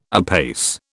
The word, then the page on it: apace: British English